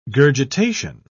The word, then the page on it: gurgitation: British English